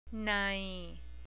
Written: nai

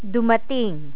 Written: dumating

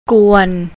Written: kuan

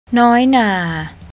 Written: noinar

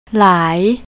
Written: lai

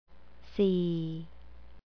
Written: see

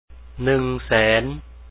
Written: 100000

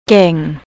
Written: keang

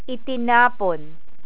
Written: itinapon

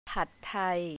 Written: pad thai

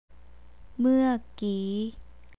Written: muakee

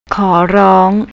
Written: korrong

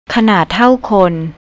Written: kanardtawkhon

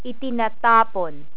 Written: itinatapon

